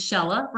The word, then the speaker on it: Shehla: She told me it rhymes with Stella and it still scares me, but Shehla Rooney. Tell us a bit more about you. – Well, my name is Shehla Rooney.